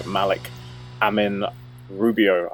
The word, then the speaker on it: Malik Amin Rubio: All right, ladies and gentlemen, I am back with another episode, and today we are joined once again by Malik Amin Rubio.